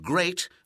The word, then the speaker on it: great: Watch out for meat and great and threat. They rhyme with sweet and straight and debt.